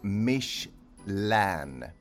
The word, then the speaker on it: Michelin: Namnet uttalas Michelin.